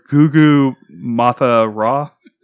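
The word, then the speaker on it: Gugu Mbatha-Raw: This starred Gugu Mathura, right? As Kelly. – You don't pronounce the M, but whatever.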